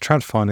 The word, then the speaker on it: TradFi: So TradFi, it's pronounced TradFi, Niels, which stands for traditional finance, which is what the Bitcoin people call us, basically.